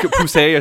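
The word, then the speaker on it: Poussey: Like Poussé, I think is how you pronounce it.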